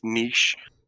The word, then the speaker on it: niche: There was a huge debate of, whether it's pronounced niche or niche. And I think it's what? Like, the original French pronunciation is niche, which is where it originates from, I believe. And then, but I think it's also called niche in the States.